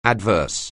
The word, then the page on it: adverse: anglais